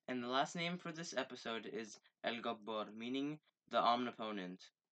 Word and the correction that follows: Al-Jabbar: I'm sorry for the pronunciation. It's called Al-Jabbar.